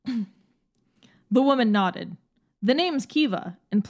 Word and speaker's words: Caoimhe: And Kiva is spelled C-A-O-I-M-H-E, as you can see, an English reader in the Western world, that might be a little difficult to pronounce because it's clearly Irish.